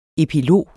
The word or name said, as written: EPILOGER